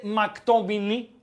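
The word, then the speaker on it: McTominay: Που διαβάζεται όπω να είναι δεν είναι πολύ εύκολο όταν αυτό που διαβάζεις δεν γνωρίζεις τι είναι. Δηλαδή, εσύ δεν γνωρίζεις ότι είναι ποδοσφαιριστής που λέγεται μακτομινή, οκ, okay? – Ναι.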